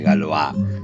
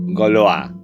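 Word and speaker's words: Gauloises: Y digo Gaulois porque el otro día una amiga que habla perfectamente francés me dijo, lo estás pronunciando mal, no es Gaulois, es Gaulois.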